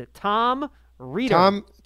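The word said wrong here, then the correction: Rehder: Tom Raider, yes. Yes, it's Raider, by the way.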